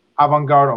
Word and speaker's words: Avangard: Avangard Omsk.